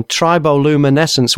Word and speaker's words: Triboluminescence: And the current album, which I'm sure you'll be showcasing in the shows, is your 14th solo album, Triboluminescence.